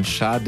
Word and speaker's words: Sade: chillige und entspannte Grooves von Sade, wie ich gerade gelernt habe, sie richtig ausgesprochen wird, obwohl ich sie schon so lange so gerne höre.